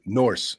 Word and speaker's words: Norse: So, North mythology. Wait, wait, wait. Did I say that right? Norse, uh, Vikings.